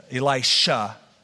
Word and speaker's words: Elisha: In chapter 4, we have a couple little highlighted stories, a couple little cameos of the prophet Elijah, Elisha. I better really say that correctly tonight.